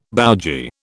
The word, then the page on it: bougie: British English